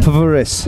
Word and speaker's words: PVRIS: But I must explain to you on my podcast, I have this thing where bands with the V, I pronounce the V. – Yeah, it's fine. – PVRIS.